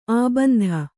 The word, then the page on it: ಅಬಿಂದು: abindu